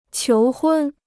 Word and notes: 求婚: qiú hūn

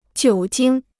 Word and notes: 酒精: jiǔ jīng

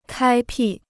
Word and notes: 开辟: kāi pì

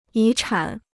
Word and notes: 遗产: yí chǎn

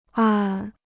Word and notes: 暗: àn

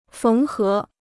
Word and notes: 缝合: féng hé

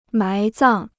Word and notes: 埋葬: mái zàng

